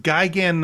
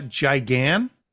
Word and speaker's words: Gigan: And it's pronounced Gigan, not Gigan.